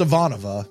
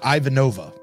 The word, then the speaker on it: Ivanova: And perhaps most importantly, we learned that it's pronounced Ivanova and not Ivanova.